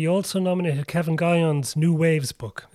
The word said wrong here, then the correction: Nguyen: So it's Nguyen, is how you pronounce it.